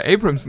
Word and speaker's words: Abrams: Kannst ja Abrams noch nicht mal richtig aussprechen. – was habe ich denn gesagt? Abrams oder? – Was? Ja, ja, Abrahams, äh, Abrahams war es äh, oh, gerade ja, Mensch.